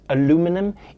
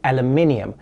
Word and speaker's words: aluminium: By the way, for the rest of the segment, I'm going to be pronouncing it aluminum, even though the correct pronunciation is aluminium.